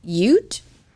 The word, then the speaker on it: Ute: Tom compiled a team of frontier lawmen to go undercover with him, including a Ute Native American.